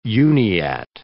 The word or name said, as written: Uniat